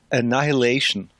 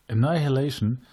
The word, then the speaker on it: Annihilation: Ja, das wird, das wird leider total komisch ausgesprochen, dieses Wort. Das ist Annihilation. – Annihilation.